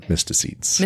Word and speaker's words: mysticetes: Also known as the mysticetes. – Mysticetes. – Okay. That one I did look up. – Good job. – And wrote down the phonetic pronunciation.